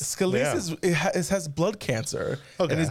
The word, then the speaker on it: Scalise: So the two individuals that are in the running right now are Jordan and Scalise.